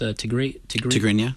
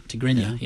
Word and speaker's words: Tigrinya: How do you say that? Tigrinya. Tigrinya, yeah. Yeah, I can mm-hmm. never say that word.